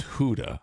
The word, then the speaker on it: Gouda: Gouda is not Gouda, which bothers me. – Oh. – Because I don't like change. It's Huda.